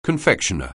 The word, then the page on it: confectioner: anglais